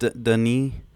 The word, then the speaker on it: Denis: Oh, it's pronounced Denis.